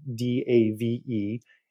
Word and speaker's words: Dave Albano: And that is the correct pronunciation of my name, by the way. Some you nailed it, Jenna, but some people totally butcher it. I get Albino, Albino, Albono, all the variations. It is indeed Albano.